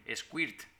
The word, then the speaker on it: squirt: Squirt. – Squirt. – Se pronuncia squirt, que significa un chorro.